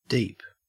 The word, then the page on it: deep: Australian English